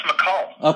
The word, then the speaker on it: MACUL: How do you pronounce that? – Oh, that's McCall.